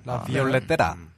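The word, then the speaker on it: La Violetera: 근데 그 중에 제일 먼저 나온 게 달리아라고 하는 가수의 뭐라고 읽어야 되냐? 라 비올레타라? – 라 비올레테라. – 아, 레테라. 라 비올레테라.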